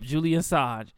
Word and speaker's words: Julian Assange: Julian Assange, right? – Yep, Julian Assange.